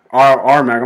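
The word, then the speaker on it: Aremac: It's actually Airmark. Is that how you say it? R-Mac, I'm sorry. R-Mac. It's camera spelled backwards.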